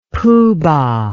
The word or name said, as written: poobah